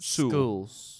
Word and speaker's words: Sewell: Shewell. – S-O-O-L is how you pronounce it. Sewell.